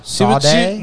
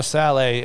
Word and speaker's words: Sallay: Sade? – No, Saleh, S-A-L-L-A-Y.